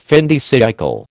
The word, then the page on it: fin de siècle: American English